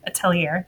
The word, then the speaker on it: Atelier: No, then we went to Atelier, which is how they say it there. Atelier. They say it with the R, so I'm going to say it how they pronounce it.